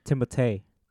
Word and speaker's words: Timothée: Apparently, his name is pronounced Timothée. – Timothée. – Yeah, because it's French.